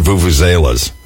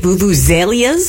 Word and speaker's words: vuvuzela: That was the worst part about watching the World Cup in South Africa that one time, where those blooming vuvuzelas. – Is that how you say it? Vuvuzelias? – Yeah, it's vuvuzela, vuvuzela, whatever you want to say it.